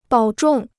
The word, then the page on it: 保重: bǎo zhòng